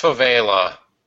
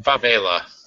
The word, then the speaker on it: favela: Uh, and it's pronounced favela. Thank you. Favela.